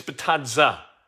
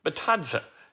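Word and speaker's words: Bitadze: And it's Batadze, not Batadze or Batadze. Batadze.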